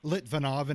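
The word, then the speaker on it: Litvínov: So, growing up in your native uh, Litvinov, and excuse me if I pronounced it wrong.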